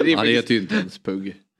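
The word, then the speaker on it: Pugh: Pug Rogefeldt. – Där måste jag rätta uttalet faktiskt. – Ja. – Pug. – Sa jag Pug? – Pug, sa du.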